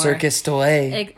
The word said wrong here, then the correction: Cirque du Soleil: or however you, how do you say? – Cirque de Soleil? – Cirque. – It's definitely Cirque.